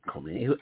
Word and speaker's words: Khomeini: Khomeini? I don't care if you pronounce it Poopmeini. – I thought it was with a K-H. – But I think it's Khomeini. I don't think they pronounce the cave.